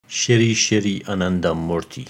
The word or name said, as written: Shrii Shrii Anandamurti